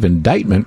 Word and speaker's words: indictment: Dean, helpful texter from the 847 says, Steve, indictment rhymes with excitement.